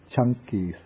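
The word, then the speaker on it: Caṅkī: This is called the Chanki Sutta. In Pali, the C, it's pronounced like CH.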